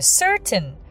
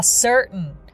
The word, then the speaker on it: certain: さあ、今日なんですけれど、今の certain でお分かりのように certain t を発音する場合もあるし、まあ certain というように t を発音しない場合もあって、こう英語ってなんか t があるのになんで発音しないのみたいなのも結構あったりするんですよね。